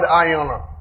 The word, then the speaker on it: aion: The word walls there is the word ion. It's the, I spell it for you, A-I-O-N.